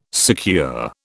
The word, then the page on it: secure: British English